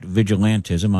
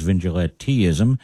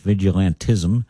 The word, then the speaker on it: vigilantism: By the way, our producer looked it up, and it is, in fact, vigilantism. I've been mispronouncing it as vigilanteism. That is not correct. It's vigilantism. And Dr. Milburn knew that because he's been pronouncing it correctly the whole time.